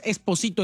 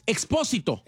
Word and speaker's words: Expósito: No es Expósito, es Expósito.